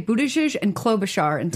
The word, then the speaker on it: Buttigieg: Can we talk about Pence and Buttigieg? Is that how you say it? It is. I believe so.